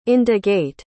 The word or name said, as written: indagate